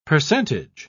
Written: percentage

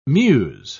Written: muse

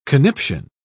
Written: conniption

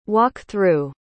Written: walk-through

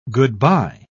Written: goodbye

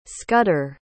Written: scudder

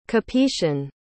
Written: Capetian

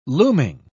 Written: looming

